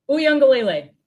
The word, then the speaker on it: Uiagalelei: Uiagalelei.